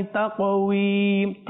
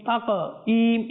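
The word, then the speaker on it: তাকওয়িম (تقويم): লাকাদ খলাকনাল ইনসানা ফি আহসানি তাকওয়িম, তাক কফ, ত তাকওয়িম, তাকঈম, ঈম বললে হবে না, উই উই, তাকওয়িম উইম। আপনি চেষ্টা করুন, আরেকটু চেষ্টা করুন, তাকওয়িম, হ্যাঁ তাকওয়িম, চলে আসবে ইনশাআল্লাহুল করিম, সহি শুদ্ধ হয়ে যাবে ইনশাআল্লাহ।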